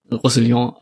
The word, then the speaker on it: Roussillon: Ähm, Brooks hat da wieder häufig Steffen gefunden und, und ich glaube, äh, Rousselier, ähm, oder wie auch ausgesprochen Roussillon. wird, Roussillon, ähm, danke.